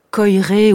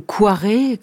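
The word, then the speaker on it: Koyré: c'est Alexandre Coiré ou Coiré, selon les prononciations.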